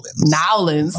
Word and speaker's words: Nawlinz: They said Nowlands. – Okay. I know it's spelled N-A-W-L-I-N-Z. Nowlands.